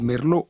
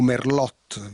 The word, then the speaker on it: Merlot: Del Ticino conosco bene il Merlot o Merlot, visto che lo pronunciamo spesso all'italiana, ed è un eccellente rosso, molto rotondo, molto suadente.